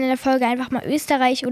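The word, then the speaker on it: Oesterreich: Das Ehepaar Fred, Österreich übrigens ausgesprochen, Eckestrike. Ich weiß nicht, ob ich mir das merken kann, aber es ist eigentlich cool. Eckestrike. Wird aber geschrieben wie Österreich mit OE vorne.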